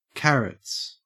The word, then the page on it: carrots: Australian English